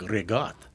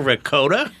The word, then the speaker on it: ricotta: It's like rigotte. Yeah. Not ricotta. Am I right? – You're right. – Yeah, yeah. – Oh, I cringe. – It doesn't spell that way. It doesn't matter. That's how it's pronounced.